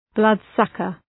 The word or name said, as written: bloodsucker